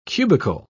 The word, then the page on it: cubical: British English